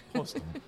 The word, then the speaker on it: Prost: And wait, I should say Prost. Did I say it right? – Yep, Prost.